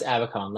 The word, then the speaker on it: Abacon: on LinkedIn under Chris Abacon. Last name is spelled A Bacon.